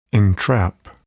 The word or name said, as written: entrap